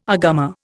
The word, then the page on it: agama: Indian